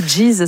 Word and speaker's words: Geese: Plus, je pense que c'est Geese, ouais. – Ouais, Geese. – Je dirais. – Ouais, parce que Geese, ça fait bizarre.